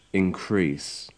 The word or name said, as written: increase